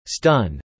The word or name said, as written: stun